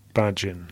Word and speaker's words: Bajin: Have we got a new pronunciation for 2021 of Sasha Bayan? Bajin. – Ah, my understanding is that it's Bajin.